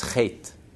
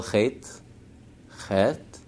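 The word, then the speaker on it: chet (חטא): As the Baal Shem Tov said, the word for Hebrew for sin is chet. How do you spell chet? Chet, tet, aleph. But you don't hear the aleph. You don't pronounce the aleph. You just pronounce the chet and the tet. Chet, chet. The aleph is silent.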